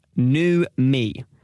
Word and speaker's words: numi: It's spelled N-U-M-I, new me.